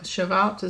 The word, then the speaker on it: Shavuot: Sivan 6 at Pentecost, or Shavuot, is how you pronounce it.